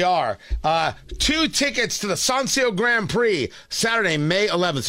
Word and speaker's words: Sonsio: It's pronounced Sancio, by the way, S-O-N-S-I-O, the Sancio Grand Prix.